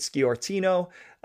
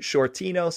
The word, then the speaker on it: Sciortino: And I'm just going to say, last episode, I pronounced it Schiortino, and I've been told it's actually Schiortino.